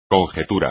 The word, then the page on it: conjetura: espagnol